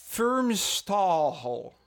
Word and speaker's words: Firmstahl: No, it was Aaron Firmstahl. – Sure. – You don't pronounce the H at the end.